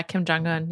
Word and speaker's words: Kim Jong-un: Kim Jong-un, yeah.